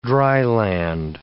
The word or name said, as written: dryland